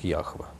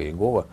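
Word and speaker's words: Яхве: А Иеговы, большинство лингвистов, сходится во мнении о том, что вот эта тетраграмма, надписание имени Бога, правильно было бы произносить как Яхва, а не как Иегова, что это ошибочный вариант.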